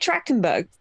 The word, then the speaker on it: Trachtenberg: Dan Tachtenberg. Tachtenberg? – Isn't it Trachtenberg? – Tachtenberg? – I thought it had an R in it. – It might be. Sorry. – I thought it was Trachtenberg.